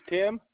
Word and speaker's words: Tim: My first name is Tim, T-I-M.